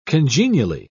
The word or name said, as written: congenially